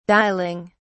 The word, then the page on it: dialing: British English